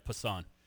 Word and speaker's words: Passan: Is it Passan? – Yeah. – Is that how he pronounces it? – Yeah, Jeff Passan.